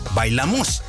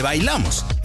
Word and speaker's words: Bailamos: Curiosamente, el título se canta con el acento cambiado, Bailamos, en vez de Bailamos. El compositor británico Paul Barry no hablaba español y aprendió mal la pronunciación.